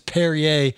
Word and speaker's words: Perrier: I don't even know how to say it. P-E-R-R-I-E-R. – Perrier. – There you go.